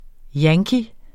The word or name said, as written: yankee